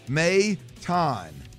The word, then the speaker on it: Maton: Pronunciation, M-A-Y-T-A-H-N, Maton.